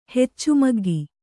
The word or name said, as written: ಹೆಚ್ಚುಮಗ್ಗಿ